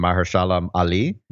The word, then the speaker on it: Mahershala Ali: I don't know how to say his name. Mahershala Ali. – Yeah, that's actually very good.